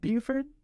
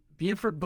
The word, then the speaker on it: Beaufort: But no, no, there's also the Buford. – Buford Buford. – Well, I call it Buford, he calls it Buford. – It depends on what southern state you're from, how you pronounce it. – How's it smell? – Southern state, neither are you. – B-E-A-U-F-O-R-T.